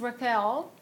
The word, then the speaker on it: Raquel: My name is Raquel.